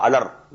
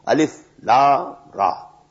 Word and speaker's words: Alif Lam Ra: These are pronounced separately, and so they are called, they are the broken into pieces. Not pronounced as Alif Lam Mim Alam, no. Alif Lam Mim. Alif Lam Ra Alar, no. Alif Lam Ra. Now the meanings of these broken alphabets, exact meanings we don't know.